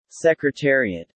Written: secretariat

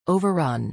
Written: overrun

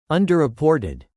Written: underreported

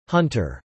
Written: Hunter

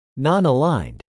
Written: nonaligned

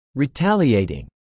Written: retaliating

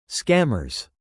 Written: scammers

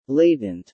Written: latent

